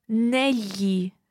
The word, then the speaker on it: negli: It's spelled N-E-G-L-I. So that G-L is the same GL that you hear in the word tagliatelle. So it's a very common sound in Italian, but in this word, it's pronounced negli. Negli. It's like writing N-E-L-Y-I, if you like.